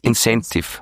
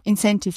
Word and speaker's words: Incentive: Ihr bietet auch Inventiv-Reisen an, wenn ich das richtig ausspreche. Was Incentive. – Das? Incentive, Entschuldigung.